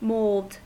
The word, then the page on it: mold: American English